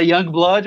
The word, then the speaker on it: Yungblud: É, se, de, o nome dele é Young Blood, mas se escreve Y-U-N-G-B-L-U-D.